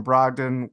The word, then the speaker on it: Brogdon: The last two games, the Bucks been without Malcolm Brogdon with a calf soreness. That is how you say that.